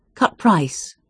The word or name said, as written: cut-price